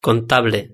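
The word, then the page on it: contable: European Spanish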